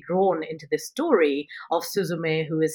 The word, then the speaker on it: Suzume: I mean, I'm going to say it like you'll hear it in the film. When there's the cutest cat in the world in this beautiful Japanese anime, and the cat will say, Suzume, Suzume. And it will ring in your ears after seeing this absolutely stunning animation.